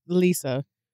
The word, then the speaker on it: Lissa: Lissa.